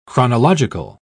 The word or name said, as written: chronological